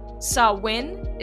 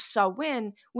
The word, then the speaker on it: Samhain: And by the way, Samhain is spelled S-A-M-H-A-I-N. So it looks phonetically like it should be pronounced Samhain, but it's not. Samhain. Gaelic is different.